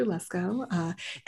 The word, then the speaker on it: Lesko: And so we had a 4-hour meeting of like, hi, so my name's Dr. Lesko. That's L-E-S-K-O. And that's how you say it and spell it.